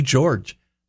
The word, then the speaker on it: Gyorgy: And her name is spelled a little differently, G-Y-O-R-G-Y, but it's pronounced George.